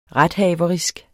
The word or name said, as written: rethaverisk